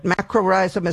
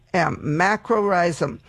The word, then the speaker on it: macrorrhizum: Macrorhizum is spelled M-A-C-R-O. R-R-H-I-Z-U-M, macrorhizum. And that's the one you want.